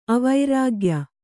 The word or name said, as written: ಅವರ್ಗೀಯ